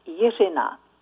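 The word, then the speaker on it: Jiřina: Hi, this is Jirina Martin. My first name, Jirina, is a Czech name, and in Czech pronunciation it sounds Jirina.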